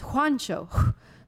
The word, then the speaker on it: Juancho: The J in Spanish is a H sound, so it's Juancho. Juancho.